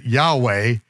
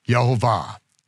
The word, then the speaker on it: Yehovah: Those three vowel pointings let us know that regardless of the fact that I pronounced it Yahweh at least a million times in my life, yet once the evidence came out and the documents came forth, then we knew how it was to be pronounced. Yod He Vav He, with the vowel pointings, Yehovah. And that is why Yeshua said,